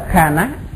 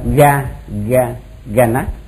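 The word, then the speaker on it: ghāna: Kanapasada, chúng ta gặp cái chữ này chúng ta cứ đọc là kha ná, đọc kha ná là nó trúng, chứ chúng ta đừng thấy vậy chúng ta đọc ga ga ga ná thì đọc vậy sai.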